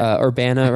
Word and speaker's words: Urbana: Did I pronounce that? – Yes, you did. – Urbana, right? Okay.